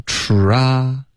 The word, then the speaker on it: try: 需要嘟嘴吃，然后再张开嘴巴 try, 然后再滑到一个 e, try, 所以跟中文的一些发音比如 try, try 的这些发音是不一样的，所以这一定要练好，因为它在口语中是一个高频词 OK, 要练这个嘟嘴的 try,